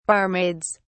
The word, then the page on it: barmaids: British English